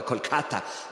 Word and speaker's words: Kolkata: E per 17 anni è una suora di Loreto e vive col suo velo nero e vive a Calcutta lo pronuncio all'italiana, sapete che la pronuncia realmente sarebbe qualcosa come Kolkata, ma noi in Italia siamo abituati a pronunciarlo come lo vediamo scritto tradizionalmente.